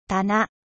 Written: etragere

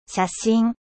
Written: photo

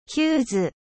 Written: fusible

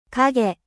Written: ombre

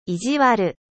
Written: mechant

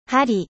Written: 針

